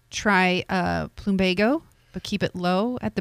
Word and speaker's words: Plumbago: How do you pronounce this? Plumago. – Plumbago? – Plumbago. – Plumbago? – Plumbago. – Plumbago? – Right.